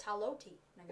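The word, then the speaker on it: Haloti Ngata: All right, well, talking about weird names, Haliotti Nagata, the defensive tackle from Oregon. – And that's not how you pronounce his name, by the way. It's Haloti Nagata.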